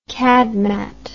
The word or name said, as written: cadmat